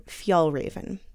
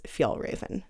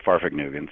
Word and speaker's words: Fjällräven: Well, of course, most of my friends like to tease me and say Farfugnugan, so that's probably the one I hear the most.